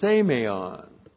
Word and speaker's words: semeion: Now, in all of those verses, that word, miraculous sign, is the Greek word semeion. Looks like this in the Greek. S, long E-M-E-I-O-N.